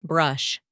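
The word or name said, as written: brush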